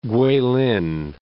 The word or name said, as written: Kweilin